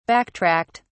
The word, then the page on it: backtracked: British English